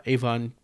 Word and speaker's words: Avon: Avon pronounced the same way as the cosmetics line nowadays.